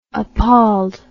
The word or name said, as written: appalled